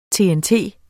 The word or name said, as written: TNT